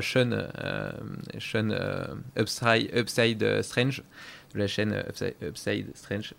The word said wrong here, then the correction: Strength: je la prononce pas bien. Strength.